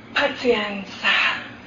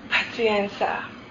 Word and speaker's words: Pazienza: And when difficulties arose, the old people used to say, Pazienza. And they say it like soothing prayer music, not some of our oh, be patient. Pazienza. And with that last ta, you exhale and you slow down and breathe.